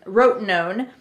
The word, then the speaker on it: Rotenone: So you guys were talking about this, how do you pronounce it again? – Rotenone.